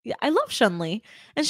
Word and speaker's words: Chun-Li: Chun-Li, okay.